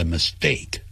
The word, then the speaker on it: mistake: 所以 be responsible for the mistake M-I-S-T-A-K-E 后头有 t 的音，念成 d mistake，所以 pucked 在这个 s 后头念成 bugged，像裙子叫 skirt S-K-I-R-T，你就不要念成 skirt， stop 你不要念成 stop，啊，都不可以这样念的。